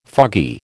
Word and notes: foggy: American English

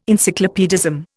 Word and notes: encyclopaedism: Indian

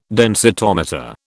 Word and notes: densitometer: British English

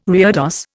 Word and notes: reredos: Indian

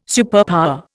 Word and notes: superpower: Indian

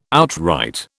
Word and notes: outright: British English

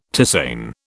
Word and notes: tisane: British English

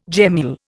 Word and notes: gemmule: Indian

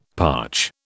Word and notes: parch: British English

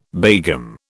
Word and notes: begum: British English

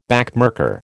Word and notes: backmarker: American English

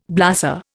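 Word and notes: blasé: Indian

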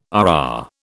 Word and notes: RR: British English

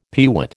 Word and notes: peewit: American English

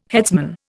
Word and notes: headsman: Indian

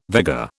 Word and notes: Vega: British English

